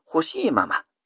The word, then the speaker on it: 恣: D の 8 番、意図編に従うとか恣意的なんていう時の恣の字、これを欲しいままと読みます。